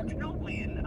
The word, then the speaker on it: Denobulan: D-E-N-O-B-U-L-A-N. – Denoblian. – Denoblian. – Denoblian? – Oh, Denoblian. Oh, okay. – Oh, yeah, Denoblian. – can you read the question again? – Which chief medical officer is a Denoblian? – Oh, that would be Dr. Flock.